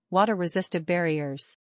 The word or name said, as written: Water Resistive Barriers